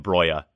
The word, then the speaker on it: Broja: Yeah, so if it does happen, I want to let every Serie A fan know right now how to pronounce his name, because us Chelsea fans got it wrong for a long time. We were all saying Broja, and then finally Southampton asked him and he said Broja.